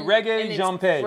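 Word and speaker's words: Regé-Jean Page: Reggae Jean Page.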